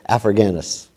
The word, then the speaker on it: Africanus: The Roman Christian historian Sextus Julius Africanus, you know, well, that's how you say it, Africanus.